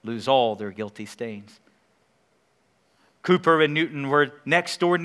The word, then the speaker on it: Cowper: Actually, it's pronounced Cooper, but it's spelled Cowper.